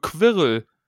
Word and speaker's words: Quirrell: Quirrell.